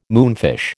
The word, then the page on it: moonfish: American English